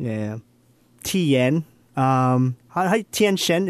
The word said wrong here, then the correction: Tien Shin Han: is that how they say it here? – Or? Tien Shin Han. – Tien Shin Han. Gotcha.